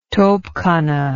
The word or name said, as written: topkhana